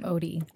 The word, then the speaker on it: Odie: Odie. – Odie. Mm-hmm. I was saying it wrong. I was saying like ode something.